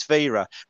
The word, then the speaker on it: Vera: Um, You might want to pronounce his name slightly different if you're French, but I didn't. It's Vera.